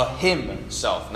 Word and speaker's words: Hymnself: So I've titled this sermon, as you can see there, The Hymnself. Now notice how it's spelled there. I'm pretty proud of it. Let me spell it out for you H Y M N, self. Him, like a song.